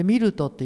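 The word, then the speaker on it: 美流渡: でミルトっていう美しく流れる渡るって書いてミルトって読むんですけどあの小さな、えー、 300 人の、まあ、限界集落ですよねそこに住んでます。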